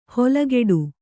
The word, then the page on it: ಹೊಲಗೆಡು: holageḍu